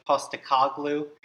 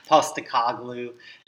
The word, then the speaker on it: Postecoglou: Postecoglou. Fun name. He's Greek. – Yeah, say that 10 times fast. – Postecoglou, and maybe I'm even mispronouncing that, so any fans don't give me too hard of a time, but he's Greek-Australian.